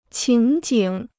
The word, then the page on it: 情景: qíng jǐng